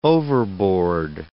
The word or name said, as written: overboard